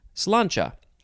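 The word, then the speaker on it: Sláinte: Slancha. Oh, and it is spelled S-L-A-I-N-T-E, is that? Yes, S-L-A-I-N-T-E, and there's an accent on the A, down on the left, up on the right.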